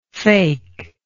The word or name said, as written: fakement